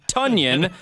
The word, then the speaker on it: Tonyan: Because the Packers PR announced this week that his name is actually pronounced Tonyan, which rhymes with Funyan.